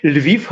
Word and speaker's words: Lviv: Lviv hast du wunderbar ausgesprochen.